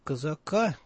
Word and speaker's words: Козак: Так что вот с моей точки зрения, из всех преемников, которых называл Путин, вот Козак, пожалуй, был бы самым толковым. – Ну что, как раз фамилия Казака, кстати, он, по-моему, сам предпочитает, чтобы вот так произносили фамилию, как раз очень редко назывался в качестве потенциального преемника.